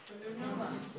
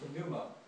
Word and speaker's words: pneuma: Pneuma. – Pneuma. Now it's not pneuma, you know, in Greek it's pneuma. See, in English you pronounce the B.